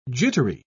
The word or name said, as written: jittery